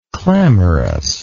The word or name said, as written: clamorousness